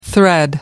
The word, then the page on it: thread: American English